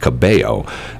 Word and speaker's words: Cabello: I don't understand the L thing because her first name is pronounced Camila. And her last name is pronounced Cabello. And the first name, you pronounce the L's, and the second name, you don't.